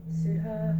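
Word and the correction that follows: saddhā: I think I'm saying that right. What was the verb for this? Oh, sadha. – Sadha. – It's S-A-D-D-H-A, just for your note-taking.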